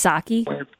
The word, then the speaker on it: Psaki: I think it's Psaki.